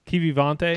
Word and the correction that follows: Kiviranta: Keevy Ronta. – Kiviranta. Kiviranta.